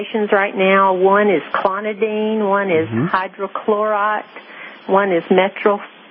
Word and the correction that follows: metoprolol: I can't pronounce it. Metoprolol. – It's called metoprolol.